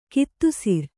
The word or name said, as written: ಗೋಧಿಸರ